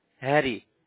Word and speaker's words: Harry: Hello, this is Harry. How may I help you? – I'm sorry, what did you say your name was? – Harry, H-A-R-R-Y.